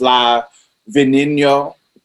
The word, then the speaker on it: La Veneno: Her name is Cristina Ortiz Rodriguez, um, better known as La Veneno. I, guess, I think that's how they actually pronounces their their their V's as like B's.